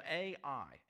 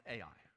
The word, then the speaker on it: Ai: And it's spelled A-I, and it's pronounced A-I.